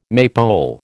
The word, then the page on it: maypole: American English